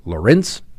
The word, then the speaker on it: Lorincz: So please meet, and I think I'm pronouncing her name correctly, Susan Lorenz, L-O-R-I-N-C-Z. Lorenz, that's where we're going with.